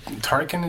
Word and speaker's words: Turrican: So I believe the next song is from the game Turrican. I'm not sure that is it. Turrican, it's pronounced. – It is.